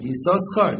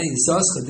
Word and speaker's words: Yisoschar: And others pronounce it Yisoschar. And others pronounce it till Parshas Pinchas. They pronounce it Yisoschar. From Parshas Pinchas on, they pronounce it Yisachar with once in different pronunciation. So he pronounced it Yisoschar.